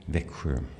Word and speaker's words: Växjö: Växjö. – Växjö, I'll never get that right.